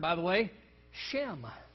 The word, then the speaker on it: Shem: He said, when I teach introductory Hebrew, the first word I typically teach is the common noun Shem. It's pronounced exactly like our English word shame. So it's spelled S-H-E-M, but it's pronounced shame.